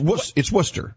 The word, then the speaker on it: Worcester: In Boston, it's Worcester, spelled Worcestershire. – Yeah, exactly. – Worcester. – Yeah. – It's Worcester. – Yes, it's Worcester. – But it doesn't look like Worcester.